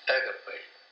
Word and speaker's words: agape: And then the big one is agape, which is the love of God.